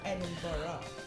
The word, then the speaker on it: Edinburgh: So funny, well, so many other people say Edinburgh because of how it's spelled. – Mm-hmm. – But I think it's supposed to be Edinburgh. – Is it Edinburgh? Edinburgh. – Like a burger. – Peterborough. – Mm. – It's Edinburgh.